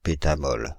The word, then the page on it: pétamole: [pe.ta.mɔl]; /pe.ta.mɔl/; Paris; France (Île-de-France)